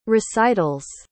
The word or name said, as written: recitals